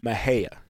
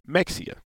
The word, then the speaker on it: Mexia: So the proper way to say it is Mahia. – That's what I thought. Okay. – Exactly. If most times people from the north, they say Mexia.